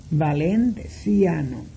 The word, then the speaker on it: valenciano: Se escribe igual en español que en esperanto, pero como la C dijimos ya que se pronuncia como TS y cada vocal forma una sílaba, la palabra valenciano en esperanto se pronuncia valenciano.